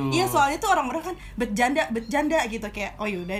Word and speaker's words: Betjanda: Jadi bacanya adalah bercanda. – Oh, bercanda. – Kan TJ ejaan dulu oh, adalah iya, C.